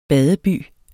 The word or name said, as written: badeby